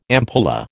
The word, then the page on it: ampulla: American English